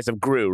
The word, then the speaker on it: Gru: You mean Rise of Gru, right? Rise of Gru. – How you say his name? I thought it was Guru. How you say his name? – No, it's just Gru. Just Gru.